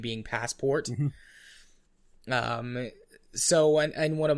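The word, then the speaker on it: Passepartout: His name is actually pronounced Passport. – I wasn't sure. It was like, yeah. – Okay, is that supposed to be Passport? Because I know it's Jules Verne, but... – Yeah, no, his name is supposed to be pronounced Passport. It's French, but it pronounces, basically Passport. You can throw a little French influence on there if you want to, but...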